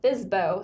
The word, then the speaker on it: FSBO: And I just recently learned that can be pronounced as FSBO, so obviously I don't know anything.